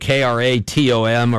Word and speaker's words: kratom: So stay away from a kratom, Kratom, or how do the fancy people pronounce that again? – Kratom.